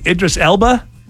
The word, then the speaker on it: Idris Elba: So a lot of people wanted it to be Idris Elba.